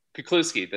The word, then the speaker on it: Kuklewski: Kuklewski, that's very Kuklewski.